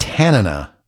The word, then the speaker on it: Tanana: I believe it's pronounced Tanana, like a town in Alaska where the band has roots.